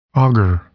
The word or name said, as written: agar